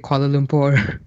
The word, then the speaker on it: Kuala Lumpur: He reads the news for Kent Brockman, this crazy character, and all of a sudden he has beautiful diction and he pronounces Kuala Lumpur just like that. Oh. How do you actually say it? – Me, I just say it Kuala Lumpur. – That's it, because that's where you are, right? – Yeah, I'm currently in Kuala Lumpur.